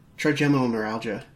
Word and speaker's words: trigeminal neuralgia: Trigeminal neuralgia. That's how it's pronounced.